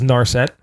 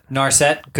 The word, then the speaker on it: Narset: this deck that you handed me, which is Narset. – Narset, good, because I was going to say that wrong.